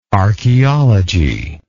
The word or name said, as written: archeologic